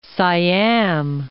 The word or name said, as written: Siam